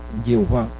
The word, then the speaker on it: Jehová: Pero Adonai, como la lengua de ellos más bien hacían como que es Adonai, entonces pusieron las vocales del Señor, el Señor, para pronunciar el Señor allá donde ponía Yahvé. donde ponían las consonantes de Yahvé, ponían E o A, y entonces luego pues algunos pues lo dijeron que eso significaba que era Jehová o Jehová o Jehová. Pero la razón de Yahvé,